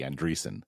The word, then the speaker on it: Andreessen: Is that how you pronounce it, by the way? Andreessen? – I always thought it was a long E, Andreessen.